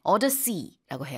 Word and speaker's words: Odyssey: 그러니까 논란은 Nolan 감독을 얘기하고 있는 거고 그다음에 이제 오디세이라고 우리는 하는데 영어로는 Odyssey. – 네. Odyssey라고 해요. 그래서 요 발음 차이는 그냥 처음부터 그냥 기억하시면 될 것 같고요.